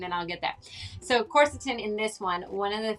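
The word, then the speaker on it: quercetin: And you had mentioned corset. Let me just say it right. Corsetin. Am I getting it? Am I even closer? – Yeah, corsetin. You mentioned... – corsetin, yes.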